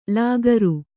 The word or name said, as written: ಳಕಾರ